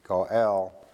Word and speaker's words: goel: The Hebrew word for redeem is G-A-A-L. At least that's what Strong said. You can also see it spelled as G-O-E-L, G-O-E-L, and it's pronounced Goel, but everything I look up comes back and says G-A-A-L, and I'm not real sure where G-O-E-L comes from, but H.P.